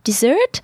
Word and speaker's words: dessert: So I hope I pronounce it well in English, actually, but dessert spelled the same way in English and in French, therefore D-E-S-S-E-R-T. So three things here. About the first E, so the second letter in the word, because it is followed by two consonants, here are two S's, the E letter, la lettre E, when followed by two consonants, is pronounced E, as in or merci. Here are the consonants R and C. So the sound E.